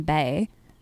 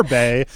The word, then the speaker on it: BAE: So I always call them Bay. – Well, because they are Bay, because they're so fucking awesome.